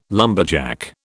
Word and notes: lumberjack: British English

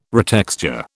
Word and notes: retexture: British English

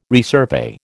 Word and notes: resurvey: American English

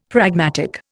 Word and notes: pragmatic: Indian

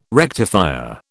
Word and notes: rectifier: British English

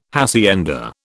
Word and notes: hacienda: British English

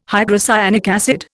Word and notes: hydrocyanic acid: Indian

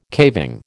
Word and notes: caving: American English

